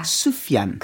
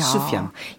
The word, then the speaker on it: Sufjan: But I've, I've recently learned it's yeah. Sufjan. 그렇죠. Sufjan.